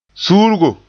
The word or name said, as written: suurgo